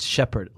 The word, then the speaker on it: Shepherd: no, it's Shepherd, like in the dictionary.